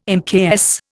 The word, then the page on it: mks: Indian